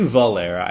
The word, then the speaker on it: Valera: It is indeed Bravik Malera. Or Valera. I knew Valera. I just was stupid, and I said it incorrectly.